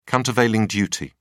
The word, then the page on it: countervailing duty: British English